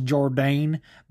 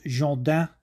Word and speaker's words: Jourdain: If you guys don't know, you probably pronounce it Charles Jourdain, but it's Charles Jourdain. That probably isn't even 100% accurate, but it's better than Charles Jourdain.